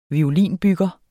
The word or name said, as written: violinbygger